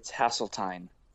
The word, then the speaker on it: Haseltine: How do you actually say your last name? Is it Haseltine, Haseltine, Hasselhoff? – It's, Yeah, any of the above. In junior high, it was Has No Friends and Ovaltine, but it's Haseltine.